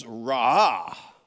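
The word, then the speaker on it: ra'ah (רָאָה): No, the Hebrew word pronunciated as ra'ah, that means to inspect, to gaze intently. It was ra'ah.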